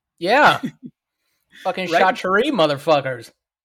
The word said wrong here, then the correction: charcuterie: Chachere. It's pronounced charcuterie.